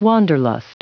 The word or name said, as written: wanderlust